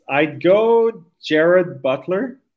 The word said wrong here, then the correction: Gerard Butler: Am I pronouncing that right? – Gerard Butler. Yeah. Gerard, I think that's a, I think Jared Butler is the Wish version. So let's stick with Gerard.